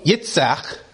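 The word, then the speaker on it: Yitzchak (יצחק): But also, Yitzchak, that's how you say Isaac in Hebrew, Yitzchak.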